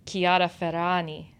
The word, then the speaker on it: Chiara Ferragni: So also it seems like Alfred, I was reading about Chiara Ferragni. Is that how you say it? – Yes.